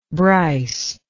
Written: Brice

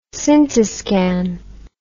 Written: scintiscan

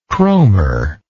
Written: cromer

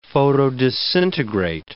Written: photodisintegrate

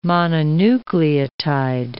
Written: mononucleotide

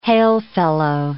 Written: hail-fellow

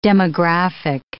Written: demographic

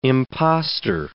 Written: impostor

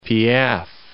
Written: Piaf

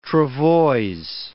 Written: travoises

